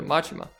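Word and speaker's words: Majima: Man skal lige have udtalt den korrekt der, Machima.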